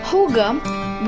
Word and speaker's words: hygge: HOO-GAH, which is spelled as H-Y-G-G-E, is the part and parcel of Danish culture.